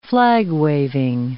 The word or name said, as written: flag-waving